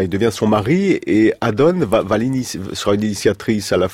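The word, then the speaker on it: Adon: C'est à ce moment-là aussi qu'il rencontre donc celle qui deviendra sa femme, Adonne Lacroix, c'est comme ça qu'on prononce Adonne.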